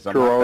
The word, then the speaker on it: Truro: WGTX in Truro. I think I'm pronouncing it right. Because Truro is correct.